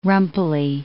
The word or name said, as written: rumply